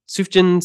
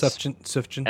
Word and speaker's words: Sufjan: Fun fact, I pronounced his name for the longest time, Sufjan Stevens. – Sufjan.